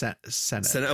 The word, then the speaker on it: Sennott: I think it's pronounced Sennott.